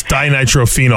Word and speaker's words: dinitrophenol: And they did it dinitrophenol. Whenever I read it, I always thought dinotrophenol. – It's dinitrophenol.